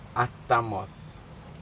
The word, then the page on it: atamod: Aneityum